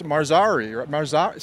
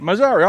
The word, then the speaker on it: Marzari: Brad, it's Marzari. Say your last name for me. – Brad Marzari. – Marzari. I see I was saying it right before I got all stressed out about it.